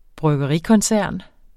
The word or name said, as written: bryggerikoncern